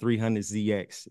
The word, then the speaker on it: 300ZX: The next track is 300ZX.